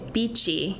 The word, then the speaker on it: bici: And the word that we use regularly is beechy, and it's spelled B-I-C-I, and that is the word for bicycle in Italian, and it's the name of our red Rhone blend.